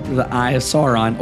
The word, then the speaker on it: Sauron: There's the Eye of Sauron.